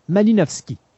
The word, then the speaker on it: Malinowski: Martin, nous nous sommes laissés la dernière fois en parlant des contributions de, de Malinowski. Est-ce que je l'ai bien prononcé? Oui, oui, oui.